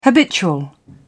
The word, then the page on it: habitual: British English